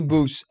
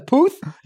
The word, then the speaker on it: Puth: What's wrong with Charlie Puth's... – Well, it's Puth, yeah. – It doesn't matter. – It does. – Oh, it does. Puth? – Yeah.